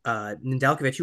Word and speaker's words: Nedeljkovic: Yeah, and I'd just like to point out that's the first time we've properly pronounced Nedeljkovic's name correctly.